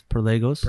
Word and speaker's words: Perlegos: Perlegos Wine. And for those at home, spell it real quick. – Yeah, it's PER, so like one per, two per, and then Legos, like the toys, LEGOS, Perlegos.